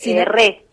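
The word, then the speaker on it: Reh: Re es mi apellido. – Perfecto, sí. – Porque termina con una H, le cuento a la gente por si te quieren googlear o seguir.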